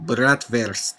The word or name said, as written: Bratwurst